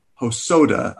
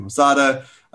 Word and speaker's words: Hosoda: Um, in conclusion, Mamoru Hosoda. I do not know why I keep pronouncing his last name Hosada.